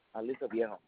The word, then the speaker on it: Aliso Viejo: It's Alisovijo. – Oh, Aliso. Aliso Viejo.